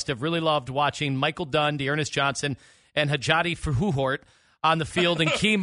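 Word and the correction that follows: Hjalte Froholdt: No, we've talked about this. It's Yelda Froholt. – Wait, Hilda Frau Farfisina? – It's Yelda Froholt.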